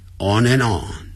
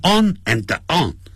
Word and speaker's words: on and on: On and on 要连接啊，产生连音，叫一直不断地 on and on，不要念成 on and on， on and on 那个 d 啊，a n d 的 d 不发音了，on and on。